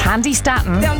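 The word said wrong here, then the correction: Candi Staton: Or Candy Staten, actually. That's how she pronounces it, and she should know.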